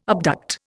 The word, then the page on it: abduct: Indian